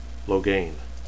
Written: logain

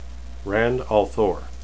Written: rand

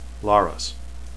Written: laras

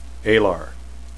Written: alar

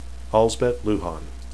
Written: alsbet

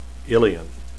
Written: illian

